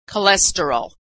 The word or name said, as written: cholesterol